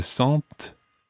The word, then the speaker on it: sentent: The word sent is spelt s-e-n-t-e-n-t. It's got a silent e-n-t at the end.